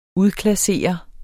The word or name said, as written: udklassere